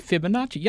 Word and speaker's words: Fibonacci: That's, is that how you pronounce it? – Fibonacci.